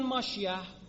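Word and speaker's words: ماشیح (משיח): در زبان عبری خیلی وقتا، نه همیشه، سین رو شین تلفظ میکنن، سلام رو میگن شالوم، نسا رو میگن نشاییم، موسا رو میگن مشه، مسیح رو میگن ماشیح.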